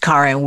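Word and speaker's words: Karin: I am your host, Karen Weary.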